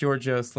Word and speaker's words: Yorgos: You can hear me say Yorgos Lanthimos at the beginning of this episode, and after we read that Prime Minister statement, I realized, wait, it would be pronounced Giorgos Lanthimos.